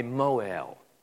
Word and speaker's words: Mohel: The practice was performed by a Mohel, M-O-H-E-L, but it's pronounced like mo, like mow your grass, and letter L, that's how it's pronounced. Mohel.